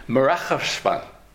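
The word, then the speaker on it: Merachashvan (מרחשון): Merachashvan. And actually, according to some, the correct pronunciation of this month is not Mar Cheshvan, but Merachashvan.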